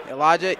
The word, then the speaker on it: Elijah: Elijah Baptiste, 14. – Spell it for me. – Elijah, E-L-I-J-A-H, Baptiste, B-A-T-I-S-T-E.